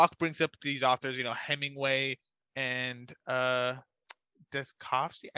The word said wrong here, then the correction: Dostoevsky: Dost, Dostoevsky.